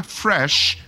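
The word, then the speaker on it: fraîche: And in the Chaguanas area, Creme Fresh, and it's pronounced, it's spelled F-R-A-I-C-H-E, Creme Fresh at the Brentwood Mall in Chaguanas.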